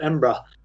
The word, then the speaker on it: Edinburgh: Not Edinburgh, it's Edinburgh. If you're really local, though, you call it Embra.